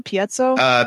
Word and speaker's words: piezo: And you know, if you go along with your your crystal idea, as far as how you say it, the piezo? – Piezo is how piezo. I say it.